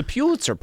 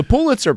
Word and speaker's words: Pulitzer: He got corrected and then mispronounced Pulitzer. It's the Pulitzer Prize. The Pulitzer Prize.